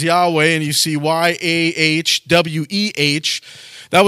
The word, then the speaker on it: Yahweh: And it is a four-letter word for God, Y-H-W-H. Most of us will pronounce it Yahweh.